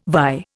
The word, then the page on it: vie: Indian